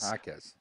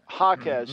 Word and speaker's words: Jaquez: But Jaquez. – Yep. Jaquez, mm-hmm. Yeah. I love that kid as a player. But I got to get his name right, Jaquez.